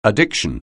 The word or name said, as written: addiction